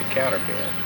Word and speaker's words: counterpane: Can you remember hearing anybody call it the counterpin? – No, I don't remember ever hearing it. – It's usually spelled counterpane, but they usually pronounce it counterpin.